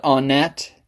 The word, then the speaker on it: Onnet: I know people have different ways of pronouncing that, but I call it Onnet.